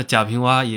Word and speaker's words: 贾平凹: 那贾平凹也有本小说，这个名字虽然是这个字是凹凸有致的凹。不是多音字，但人家作家自己都说了读蛙，所以大家也不用太纠结这个名字。